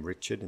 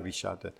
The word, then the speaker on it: Richard: And like, even so, when I often say my name Richard instead of Richard.